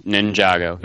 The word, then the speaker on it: Ninjago: The Lego was it Ninjago? Is that how you say it? – Ninjago, yeah.